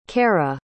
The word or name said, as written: carah